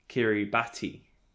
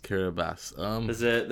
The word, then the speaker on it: Kiribati: To say how it's spelled, it's Kiribati, Yes. – which should. Which would surely be pronounced Kiribati. – Yeah. – Why is it Kiribati? – Kiribati. – They're missing a T and an I, and they've put in an S.